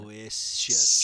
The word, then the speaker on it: Aloysius: Allo, and then the Y is the I. And then... – Aloysius. Aloysius.